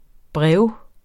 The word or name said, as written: brev